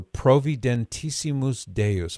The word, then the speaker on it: Providentissimus Deus: So Providentissimus Deus. I'll spell it for you. So Provident, P-R, P-R-O-V-I-D-E-N-T, provident, and then add to that word I-S-S-I-M-U-S.